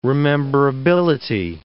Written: rememberability

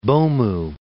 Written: Bomu

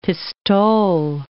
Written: pistole

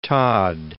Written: Todd

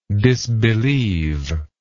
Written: disbelievingly

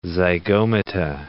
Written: zygomata

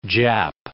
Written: Jap

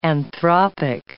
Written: anthropic

